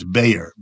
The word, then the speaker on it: Bayer: It's a German chemical company, pronounced in the United States, Bayer.